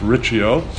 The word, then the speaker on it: Rizzio: Then she had an affair with her Italian counselor, David Riccio, or Rizzio, but pronounced Riccio.